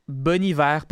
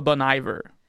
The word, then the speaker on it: Bon Iver: Puis ça se prononce Bon Hiver, pas Bon Iver.